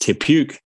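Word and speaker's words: Te Puke: Tepuke, pronounced Tepuke for those that aren't in the know.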